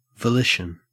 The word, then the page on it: volition: Australian English